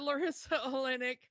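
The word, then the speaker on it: Larisa Oleynik: Larissa Orlaik, or I forgot how to say her name. Can you say her name? – We've got Larissa Olenek as Bianca, the other sister here, and completely.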